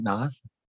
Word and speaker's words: Nas: but the Lil Nas X, is that how you say it? Nas? Yeah. Lil Nas X, I don't know.